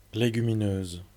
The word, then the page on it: légumineuse: [legyminøːz]; /le.gy.mi.nøz/